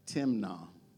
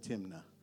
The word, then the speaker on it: Timnah: It says, now Samson went down to Timnah. That's the Hebrew way to pronounce it. I would have said Timnah.